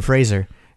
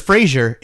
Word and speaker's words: Fraser: It's kind of like Brendan Fraser. Everybody says Fraser. It's Fraser. – Fraser. – Yeah. – Softer S.